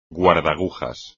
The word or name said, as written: guardagujas